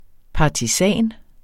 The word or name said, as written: partisan